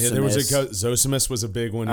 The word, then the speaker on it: Zosimus: So Zosimus.